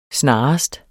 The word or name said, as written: snarest